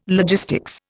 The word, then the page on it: logistics: Indian